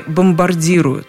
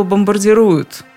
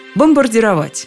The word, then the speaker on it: бомбардировать: Меня мои избиратели просьбами бомбардируют пылу ораторства этого можно и не заметить, но в ударении ошибка. Если уж что и делают избиратели, то бомбардируют. Просьбами бомбардируют господина Н. Можно, кстати, и короче сказать – бомбить. Разницы почти никакой. Но именно почти. А в языке такие нюансы – это существенная вещь. Бомбардировать. Ударение, как в словах «примировать», «нормировать».